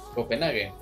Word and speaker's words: Copenhague: Bueno, y con los partidos del martes empezamos con Chelsea Dinamo Zagreb, el Dortmund contra el Copenhagen, ¿sí es Copenhagen? – Copenhague, pero está bien.